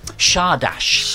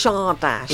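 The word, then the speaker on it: Csárdás: Now, I can't pronounce Monty. – Yes, Monty. Shardash. – Shardash.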